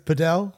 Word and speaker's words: padel: I said paddle and he said, no, it's padel.